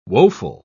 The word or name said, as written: woeful